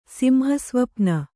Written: ಸಿಂಹಸ್ವಪ್ನ

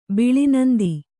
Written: ಬಿಳಿನಂದಿ

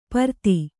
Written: ಪ್ರತಿ

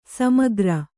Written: ಸಮಗಾರ

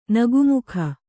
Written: ನಗುಮೊಗ

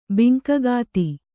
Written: ಬಿಂಕಗಾತಿ

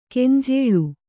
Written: ಗಂಜಳ

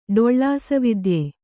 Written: ಡೊಳ್ಳಾಸವಿದ್ಯೆ

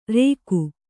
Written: ರೇಗು

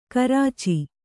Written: ಗೋರೋಚ

